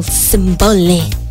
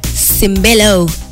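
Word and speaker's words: Sembello: 878 876 Alan White, Maniac by Michael Cimbello. Cimbello. Yeah. I know I mispronounced it the first time, but you guys, forgive me.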